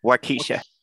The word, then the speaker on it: Waukesha: And I think on his gun, he had one of the victims' names from Wakasa, Wisconsin. And I'm probably mispronouncing that name. Wakasha, Wisconsin. – Waukesha.